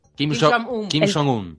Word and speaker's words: Kim Jong-un: Bueno, y de hecho en 2016, no sé si se acuerdan que hubo un problema con Corea del Norte, sí, claro, que, chicos, discúlpenme la pronunciación, ustedes saben cómo se pronuncia este hombre, Kim Jong-un. – Kim Jong-un. – Presidente. Gracias, chicos.